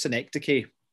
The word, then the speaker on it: synecdoche: It's pronounced synecdoche, which we only found out when we were quite young teachers.